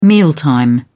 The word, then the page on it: mealtime: British English